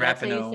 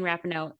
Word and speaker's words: Rapinoe: soccer Rapinoe, gal. – Megan okay, Rapinoe. Say, Ra- Megan Rapinoe.